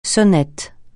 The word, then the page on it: sonnette: francais